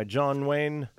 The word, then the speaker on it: Nguyen: As for David Lynch, he is the subject of a new documentary, featuring direction by John Wayne. And that's spelled Vietnamese style. Nguyen.